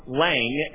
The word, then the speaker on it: Lange: The German theologian, I pronounce his name Lange, L-A-N-G-E.